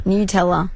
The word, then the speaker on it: Nutella: Nutella. – Is it really? – Yeah. – Nutella.